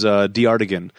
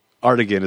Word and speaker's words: D'Artagnan: Yeah, but it's the same reason why I thought in Three Musketeers, when I grew up reading it, I thought his name was D'Artagnan. Not D'Artagnan, because it's spelled the same way in a book. It's D apostrophe Artigan is the way I read it when I was a kid.